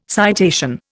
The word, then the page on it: citation: Indian